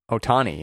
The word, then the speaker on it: Ohtani: how do you pronounce his name? Shohei Ohtani? – Ohtani, yeah.